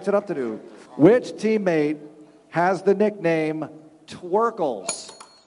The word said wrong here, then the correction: Twercules: Twercules. I read it wrong. – Who? – Well, I was reading it phonetically. It's Twercules.